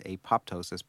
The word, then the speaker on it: apoptosis: We call that apoptosis, spelled A-P-O-P-T-O-S-I-S, apoptosis, pronounced apoptosis.